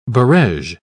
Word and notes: barege: British English

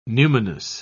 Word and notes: numinous: British English

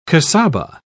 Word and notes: casaba: British English

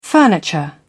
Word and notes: furniture: British English

